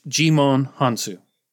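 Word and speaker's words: Djimon Hounsou: Jimon Hansu. – Glad you said that, not me. Yep. – Yep. I looked up how to pronounce it. – Good man. – Jimon, French, Jimon Hansu.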